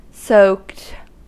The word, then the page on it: soaked: American English